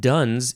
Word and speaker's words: Duns: Well, it's from John Duns Scotus, and Duns is D-U-N-S, could have been pronounced dunce, spelled differently though.